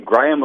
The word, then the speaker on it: Graeme: Graeme, like Graham Crackers and Simsion.